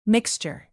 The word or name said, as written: mixture